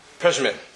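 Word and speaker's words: Przemyśl: The Mater Moshe was from Rav Moshe Avraham, from a town upon which I can't pronounce, Przemil, maybe somebody can help me out with that. Przemil, thank you.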